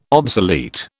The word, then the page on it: obsolete: British English